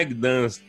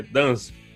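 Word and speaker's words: Gdańsk: Ele, não, não é Gdansk, é Dansk. O uhum. G é mudo.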